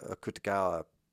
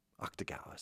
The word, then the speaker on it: Akutagawa: I will go back and I will not read it, though, because I think the actual story Rashomon is not the Akutagawa. It's actually Akutagawa. Is that how you say it? You don't say the U.